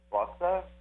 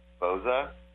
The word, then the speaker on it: Boza: But really, in Italian, it's Bossa. So to me, it should be Boza.